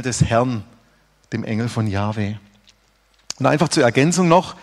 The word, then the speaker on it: Jahwe: Im Hebräischen steht dann in der Bibel JHWH, was Jahwe ausgesprochen wird.